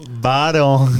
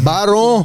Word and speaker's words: Baron: É, e Talita e eu, Ronari Nunes, vamos hoje receber o Ivan Barlon. Falei certo seu sobrenome? Baron. Baron, Baron.